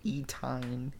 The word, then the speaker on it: Etienne: Didn't you give him a breakdown last week of how to say it? – Three letters. Just think of three. Etienne. Thank you. Etienne.